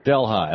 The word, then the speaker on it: Delhi: Am I saying that right, or is it Delhi? – Delhi.